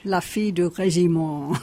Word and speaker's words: La fille du régiment: And how do you pronounce that in French? – La fille du régiment.